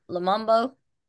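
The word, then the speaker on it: Lumumba: Lamombo? Lamombo? – Lamumbo. – Mayor Lamombo got Dexter Wade on his hands. When you get a chance, go look at the public Lamumba.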